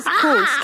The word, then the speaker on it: Knoots: It's Kuntz, K-N-O-O-T-S, Kuntz. That K, but like you said, in the English language, that K be silent. – I don't know. – But yeah, so Mrs. Kuntz,